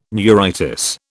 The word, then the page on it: neuritis: British English